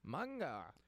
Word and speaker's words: Mungo: Mungo. – Yeah, so Mungo is spelled M-U-N-G-O, so okay.